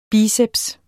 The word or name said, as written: biceps